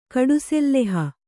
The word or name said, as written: ಕಡುಸೆಲ್ಲೆಹ